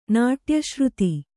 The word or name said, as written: ನಾಟ್ಯಶ್ರುತಿ